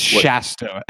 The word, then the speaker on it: Shasta: I think it's Shasta, S-H sound.